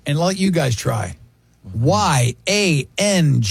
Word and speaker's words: Yangnyeom: In Korean cuisine, Yangnyeom. – Spell it again. – Wait. – Y-A-N-G. N-G. – N-Y-E-O-M.